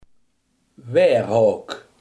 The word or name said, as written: weerhaak